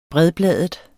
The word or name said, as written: bredbladet